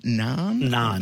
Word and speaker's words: naan: Did you pronounce it like naan? – Naan pizza. – Right. Oh, I got something right today.